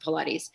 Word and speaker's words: Pilates: I didn't even know that you would pronounce it Pilates.